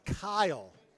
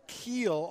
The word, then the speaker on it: Kiel: My name is K-I-E-L, pronounced Kyle. – Okay. – I get called Keel on a daily basis.